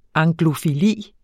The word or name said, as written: anglofili